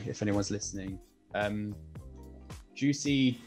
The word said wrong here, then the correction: Jussi: Jaskalainen. – Jussi Jaskalainen. Jaskalainen very high.